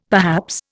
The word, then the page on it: perhaps: Indian